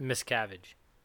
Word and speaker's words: Miscavige: Miscavige.